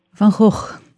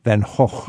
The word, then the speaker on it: Van Gogh: How do the Dutch pronounce Vincent van Gogh? – Van Gogh. – Van Gogh. – Yeah, Van Gogh. – But I think it's okay for us English speakers to say Van Gogh.